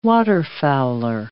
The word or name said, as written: waterfowler